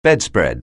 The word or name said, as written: bedspread